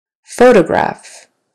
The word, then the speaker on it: photograph: Photograph. Photograph. Now it's on the first syllable.